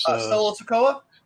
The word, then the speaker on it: Solo Sikoa: He's Solo Sikoa.